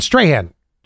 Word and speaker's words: Strachan: S-T-R-A-C-H-A-N. Strahan. – Strahan? Oh, Strahan? Like Michael Strahan? – No, not like that. – Spell it again. – S-T-R-A-C-H-A-N. Strachan? – S-T-R-A-H-C-H-N? – Yeah.